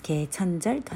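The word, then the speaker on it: ケ ー チ ャ ン ジ ャ ル (개천절): 天が開くと書いて回建設と書きます。ケーチャンジャルと発音するんですけれども、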